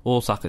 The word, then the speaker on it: Osaka: Well, I always mispronounce Osaka, right? I say Osaka. Americans say Osaka. Whereas I just... It's actually Osaka. Osaka. It's like a long Osaka. Or sound. Osaka.